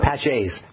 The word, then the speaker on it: Paget: Paget's disease, correct? – Is that how you say it? Thank you. – Paget's, yeah, P-A-G-E-T.